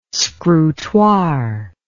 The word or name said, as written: scrutoire